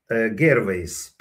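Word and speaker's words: Gervais: По-моему, Гервейс читается. Гервайс пишется.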